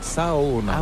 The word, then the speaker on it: Saona: En fait, on prononce chaque lettre, donc c'est Saona.